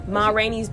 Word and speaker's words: Ma Rainey's: So August Wilson, he wrote 10 plays, including Wamini's Black Bottom. – Mm-hmm. There's so many. – Wait, did I say it right? – Ma Rainey's Black Bottom. Yes, you did.